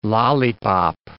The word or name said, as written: lollipop